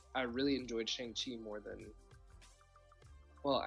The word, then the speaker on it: Shang-Chi: Shang-Chi was just a surprise.